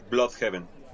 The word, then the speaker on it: Blüdhaven: ¿cuáles son las principales familias de Bloodhaven, o según como lo quieran pronunciar? – Básicamente, y en canon, es Bloodhaven. Por las diéresis.